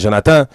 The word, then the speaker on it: Jonathan: So it's because of him, because Jonathan, well, in French it's Jonathan. So my name, my nickname would be Joe in French, but in English, it'd be John.